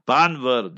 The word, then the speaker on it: Bhanvad: So now when I came back from our Gam, from our village, from where my forefathers came from, Banvar, they spell it B-H-A-N-V-A-D, Banvat, but you pronounce it Banvar.